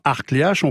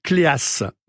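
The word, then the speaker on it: Kléac'h: K-L-E, accent aigu, A-C, apostrophe H. – Vous voyez. – Et d'ailleurs, en breton, on ne prononce pas Arcléache, on prononce Cléas. C'est-à-dire K-L-E-A-X.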